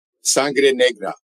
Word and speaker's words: Sangre Negra: Pronounce that again. Sangra. – Sangre Negra, Black Blood. – Yeah. – On Amazon. – You roll the R's like only the Hispanic can.